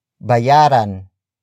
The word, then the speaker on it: bayaran: Bayaran mo ang utang mo.